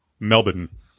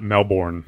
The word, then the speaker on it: Melbourne: Before we get too far, I don't know if we have a lot of Australian listeners, but the name of the city is pronounced Melbourne. – What? – Not Melbourne.